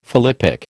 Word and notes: philippic: American English

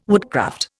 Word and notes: woodcraft: Indian

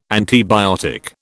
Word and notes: antibiotic: British English

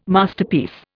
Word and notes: Masterpiece: Indian

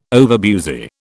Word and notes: overbusy: British English

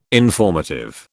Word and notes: informative: British English

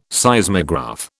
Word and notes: Seismograph: British English

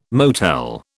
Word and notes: motel: British English

